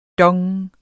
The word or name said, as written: dong